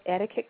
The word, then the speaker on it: etiquette: And to make sure that you remember how to pronounce the word correctly, pronouncing the Q as a K, if you say the word slowly, you hear yourself say ticket, etiquette. And that's just simply because it comes from a French word that means ticket.